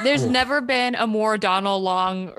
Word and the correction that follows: Donald Logue: Donald Logue? – Yeah, that guy.